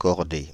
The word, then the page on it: chordés: /kɔʁ.de/; France (Île-de-France)